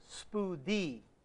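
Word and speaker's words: spoudē (σπουδή): But the Greek word for diligence is the word spoudē. It looks like a spoudē, but in the Greek it's pronounced spoudē. Say that after me.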